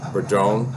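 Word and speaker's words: Padrón: And this guy was a Padron.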